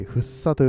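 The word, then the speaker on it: 福生: えっ、ー、と、幸福の福に生きると書いて、ふっさと読みます。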